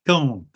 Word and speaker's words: Caen: In the same year, Henry launches a further invasion of Normandy, captures Caen, whatever it's pronounced, that town in France, in Normandy. – Caen, that's what I was trying to say, yeah.